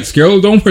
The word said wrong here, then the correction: scleroderma: is that how you say it? Scleroderma. It's an autoimmune disease.